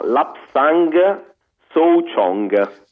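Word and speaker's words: Lapsang Souchong: Scusa, ce lo pronunci lentamente perché così chi ha preso penna e foglio può scrivere. – Esattamente, allora, si chiama Lapsang Soo Chong.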